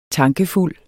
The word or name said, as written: tankefuld